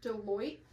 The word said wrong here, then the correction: Beloit: Well, Beloit. – Oh, Beloit, yes. It's a B.